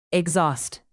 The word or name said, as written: exhaust